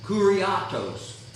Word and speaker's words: kuriotes: It is spelled A-T-H-E-T-E-O. And it gives us the idea of rejecting. Rejecting. The word dominion comes from the Greek word kuriatos. Kuriatos. Kuriatos. It is spelled K-U-R-I-O-T-E-S.